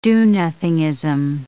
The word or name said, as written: do-nothingism